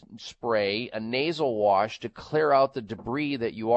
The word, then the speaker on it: Xlear: You can go to the health food store and pick up a nasal spray called Clear Nasal Spray. X-L-E-A-R is how you spell it. The X is for xylitol.